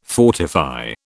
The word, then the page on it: fortify: British English